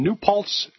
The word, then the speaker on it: New Paltz: Let's go to James in New Paltz, New York.